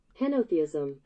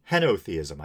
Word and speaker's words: henotheism: But I just did a quick Google search, and it looks like uh, I think my definition was mostly right, but my pronunciation was wrong. – Henotheism. – Henotheism. I said henotheism.